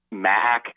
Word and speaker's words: MAAC: like first round women's games in the MAAC conference. I say it with two syllables so that people don't get it confused with the Mid-American Conference.